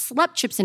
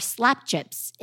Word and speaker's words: slap chips: In South Africa, particularly in Cape Town, there is a fried delicacy called slap chips, pronounced slap chips in English or slap chips in Afrikaans.